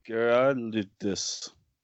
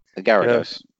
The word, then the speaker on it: Gyarados: Girardis. – Girardis? Gyarados.